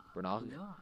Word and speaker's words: Branagh: Branagh, is that how you pronounce that, Jeffrey? B-R-A-N-A-G-H. Branagh. Maybe Branagh. Branagh.